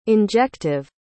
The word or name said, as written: injective